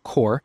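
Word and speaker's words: QORE: They're spelling it Q-O-R-E. I don't know why, but it's pronounced Core.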